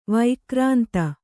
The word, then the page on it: ವೈಕ್ರಾಂತ: vaikrānta